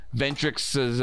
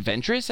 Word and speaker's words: Ventress: And it's I almost have to think that Batman took inspiration from Ventrix's uh, or Ventress. I think I, yeah, wait, it's Ventrix. It's written Ventrix, but you pronounce it Ventress. I think, right?